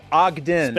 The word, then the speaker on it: Ogden: I believe it's pronounced Ogden. – Spelled O-G-D-E-N.